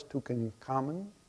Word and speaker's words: Tutankhamun: And you see, there's a picture of one of the ancient pharaohs, Tutankhamun. That's how you pronounce his name.